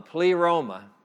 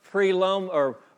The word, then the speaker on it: pleroma: Long E, long O, pleroma, okay? Everything that happened, and what this word preloma or pleroma means,